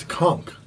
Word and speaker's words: conch: I think it's like a C-O-N-K sound.